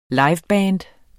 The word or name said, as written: liveband